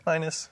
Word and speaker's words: minas: This quick text is for the parable of the 10 minas. Is that how you pronounce it? Minas? – Yeah. – Minus. – Okay. – I say minas, whatever. You say minus.